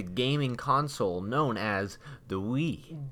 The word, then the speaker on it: Wii: W-I-I. – And a lot of people don't know it's pronounced Wii. – Wii. – Wii. – Wii. – Like, you know, as if you were saying yes in French.